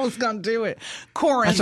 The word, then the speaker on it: Corinth: Corinth Comic Con 2023 is coming up Saturday, January 21st at the Crossroads Arena in Corinth, Mississippi. I did say that right. It's not Corinth. – It's Corinth, ma'am.